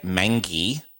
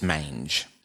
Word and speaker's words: mange: This one I followed a Michelleism and I went to the Googs and I followed the intricacies of how to pronounce it because I wanted to say mangy, but it's mange. – Yeah, mangy would have been funnier, but it's definitely mange. – It's the mange, everyone. M-A-N-G-E, the mange.